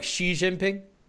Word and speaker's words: Xi Jinping: Okay, so it's more like Xi Jinping, right?